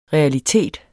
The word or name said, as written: realitet